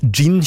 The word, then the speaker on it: jinn: Gin tonic, ja. Nei, ja, det skrives med jod. J-I-N-N. Gin. Men også det uttales gin. For på engelsk skrives det med D først. Gin.